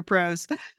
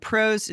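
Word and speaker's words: Prose: Couldn't be happier, PROS.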